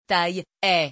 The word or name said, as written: taille-haie